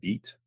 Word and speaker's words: Vite: The, the big one today is they call it Vite.